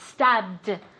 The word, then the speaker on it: stabbed: Stabbed. Okay, and that D sound at the end. Stabbed. Let's see if she can say it again.